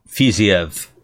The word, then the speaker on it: Fiziev: But I'd be interested to see what, how do you say it? Fiziev? – Rafael Fiziev.